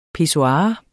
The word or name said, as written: pissoir